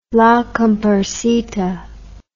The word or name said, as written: La Cumparsita